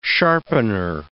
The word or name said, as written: sharpener